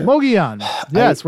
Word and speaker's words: Mogollon: So growing up then, did you hear a lot of stories about like the, tell me if I'm pronouncing this correctly, the Mogollon monster? – The Mogollon? Mogollon monster? – Mogollon. Yes, we're going to pronounce I said it correctly.